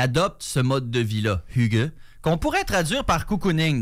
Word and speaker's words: hygge: H-Y-G-G-E. Ça se prononce hygge,